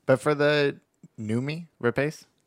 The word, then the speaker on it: Noomi: I think it's Noomi. – It's Noomi Rapace. – It's spelled like Naomi Rapace. Rapace.